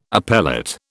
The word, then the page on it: appellate: British English